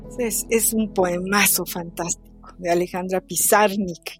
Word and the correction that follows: Pizarnik: Yo, siempre le pongo el acento en la, en la última I, pero es Pizarnik, Alejandra Pizarnik.